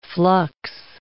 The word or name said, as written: flux